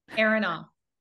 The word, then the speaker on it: Aronoff: Did I say it right? – Aronoff.